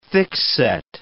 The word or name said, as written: thickset